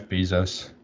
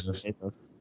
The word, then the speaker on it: Bezos: Hey, you see Jeff Bezos is it Bezos or Bezos? I always say it wrong. – Bezos. What? I believe Bezos. – Bay. He's so bay.